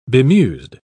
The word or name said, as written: bemused